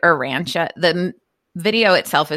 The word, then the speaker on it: Urantia: Cause the spelling is U-R-A-N-T-I-A. Yeah. Arantia. But yeah, they all, it's very, if you go look at it, it's definitely one of those like inspirational type of things.